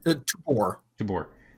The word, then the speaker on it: Tubor: As he walks off, I look up at Tubor and I'm just like, did I say that right? Sorry. Tubor? – Tubor. – Tubor? – T-U-B-O-R.